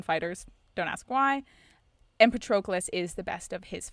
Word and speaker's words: Patroclus: So Nestor, who we talked about earlier, goes to Patroclus, and if you think Patroclus' name is pronounced Patroclus, you can just end the podcast here. That's all. I have very, very strong opinions about this. His name is Patroclus.